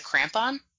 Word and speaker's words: crampon: So you had pardon me if I pronounce it wrong, is it a crampon? – Yeah.